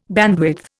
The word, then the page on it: bandwidth: Indian